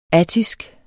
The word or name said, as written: ATTISK